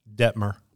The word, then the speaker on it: Dettmer: Dettmer. Two T's, D-E-T-T-M-E-R, not related to Ty or the other football players from BYU.